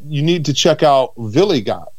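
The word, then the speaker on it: Wiligut: But Villigot, spelled with a W, of course, because there's that German pronunciation.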